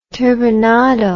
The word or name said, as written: turbinado